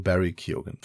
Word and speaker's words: Barry Keoghan: Ich habe nochmal nachgeguckt, nach, nochmal nachgehört und mir erklären lassen, wie das ausgesprochen wird. Okay. Auf jeden Fall heißt der Typ wohl Barry Keoghan.